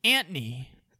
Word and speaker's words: Anthony: I think you might even be pronouncing the O a little too much. It might just be Antony. Antony. Antony.